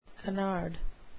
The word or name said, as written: canard